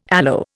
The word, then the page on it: aloe: Indian